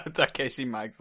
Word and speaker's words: Takeshi Miike: Aber Miike muss man ja nur falsch aussprechen.